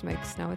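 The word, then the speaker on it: Miike Snow: Oh, no, it's pronounced Mike Snow. It's Swedish.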